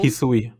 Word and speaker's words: Hisui: Ähm, wir befinden uns in der, wie spricht man es aus, Husui-Region? – Hisui. – Hisui-Region, das ist die, Hisui.